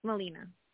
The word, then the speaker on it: Melina: Oh, Melina. – How do you spell that? M-E-L-I-N-A.